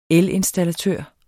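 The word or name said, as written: elinstallatør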